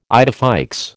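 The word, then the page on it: idée fixe: American English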